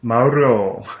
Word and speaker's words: Mauro: So this past week, I went out to Jingu Stadium and got a chance to meet and talk to Mauro. I asked him how to say his name, and he said, yeah, Mauro. He can say it really fast and roll his tongue.